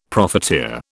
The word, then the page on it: profiteer: British English